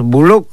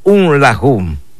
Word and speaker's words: buluk: El número 11 tiene dos formas en pronunciarlo. Decimos Buluk. O decimos un lajum. Le decimos buluk o le decimos un lajum. Es en el número 11.